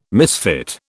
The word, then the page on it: misfit: British English